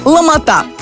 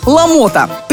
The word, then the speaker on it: ломота: В повседневной речи распространен вариант ударения на букву «а» третьего слога – «ломота». Но, несмотря на привычный вариант произношения с ударным последним слогом, этот вариант является просторечным, устаревшим и даже считается грубой ошибкой. В соответствии с орфоэпической нормой русского языка, единственный правильный вариант ударения на букву «о» второго слога – «ломота». При склонении по падежам и числам ударение на гласную «о» во втором слоге сохранится во всех формах, что облегчит запоминание.